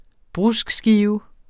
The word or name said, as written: bruskskive